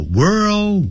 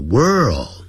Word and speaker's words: world: 那叫做 World War II, 世界那个字叫 World, 因为有一个 R, 还有一个 L, 所以先念成卷舌音 world, 然后那个 L 呢翻成欧洲的 O, world, 就 world, 好，那个 D 念得很弱，好， world, World War II, 好，就是第二次世界大战。